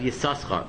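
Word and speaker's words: Yisachar (יששכר): And since the second sin was a negative thing, that Yaakov was exchanged for Dudoyim, therefore we don't pronounce the second sin and we call his name Yisachar instead of Yisachar.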